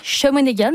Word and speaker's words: Shawinigan: Ça s'appellera Shawinigan. Je le prononce comme ça, mais c'est comme ça que ça se prononce.